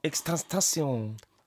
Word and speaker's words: XXXTentacion: RIP to XXXXTentacion. Did I say that right? – XXXTentacion. – RIP. – Tentaf. – They're calling him. – You got to say it with a flat tongue. XXXTentacion.